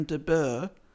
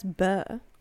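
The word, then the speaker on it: de Bourgh: In the meantime, he's a clergyman and a very wealthy noblewoman called Lady Catherine de Burr. Is that how I say that? B-O-U-R-G-H. De Burr. – Wait, spell it again. I'm dyslexic. – B-O-U-R-G-H. – Well, that's Burr. – Burr.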